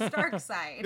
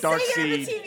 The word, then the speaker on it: Darkseid: The correct answer is Darkseid. – This feels like a very Hunter kind of thing here. – No, it's not. Panels say Darkseid. There is literal audio evidence of how you pronounce it.